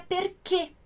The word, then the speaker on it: perché: Porque, na verdade, esse acento é um acento, como se fosse o nosso acento circunflexo. Ele dá o som fechado. Então, a pronúncia correta mesmo seria perché.